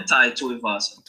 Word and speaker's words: Tuivasa: Yeah, Tai Tuivasa.